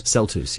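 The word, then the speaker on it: celtuce: And this, how do you say it again? Celtus? – Celtus, yes.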